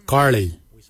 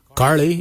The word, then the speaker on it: giilyi: Darb is spelled D-H-A-A-B. It is a traditional knife, a darb. For boomerang, we say karli. Karli is the traditional name for boomerang. Karli is spelled G-I-I-L-Y-I, karli. For the traditional stone axe, we say koch, koch. Koch is spelled G-O-O-D-J, koch.